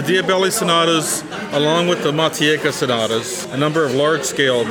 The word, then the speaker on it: Matiegka: M-A-T-I-E-G-K-A, Matieka.